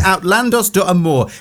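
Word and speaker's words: Outlandos d'Amour: And now, I wasn't sure how to pronounce this, but I'm going to have a go. Outlandos de Amor. Have I got that right? – Sounds good to me.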